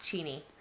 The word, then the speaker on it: Cheney: It's Cheney, but it's pronounced Cheney.